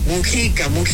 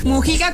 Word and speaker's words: Mújica: Mújica, luego digo mal el nombre, me regañan. Mújica con acento en la U.